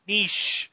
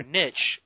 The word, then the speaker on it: niche: I already forgot how to say it. Niche. Or niche, if you're here in the USA. – In the USA, yeah, in the Americas.